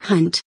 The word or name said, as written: hunt